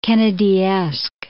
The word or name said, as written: Kennedyesque